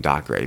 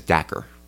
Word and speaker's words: Dacre: I've been calling him Doc Gray because phonetically, that's how it reads. His name is Dacre.